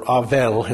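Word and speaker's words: Avel (הֶבֶל): However, in the Hebrew, the word Abel, or Avel, is how it would be pronounced in the Hebrew, is a word for vanity or breath, and you get the understanding here that it's about the temporary nature of existence.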